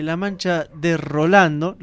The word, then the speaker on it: La Mancha de Rolando: Y pedía un tema de La Mancha de Rolando, lo dije bien, sí.